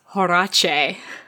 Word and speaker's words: Horace: Chapter 4, Horace Slughorn. – It's actually pronounced Horace. – Horace. Horace Slughorn.